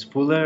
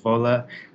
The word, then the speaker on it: Fuller: And when I introduced myself, people said, Oh, your name is Fuller. And then they immediately gave it a German pronunciation of Voller.